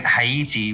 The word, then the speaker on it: Haiti: She was pronouncing it Haiti, which is the way that Haitians actually would pronounce it.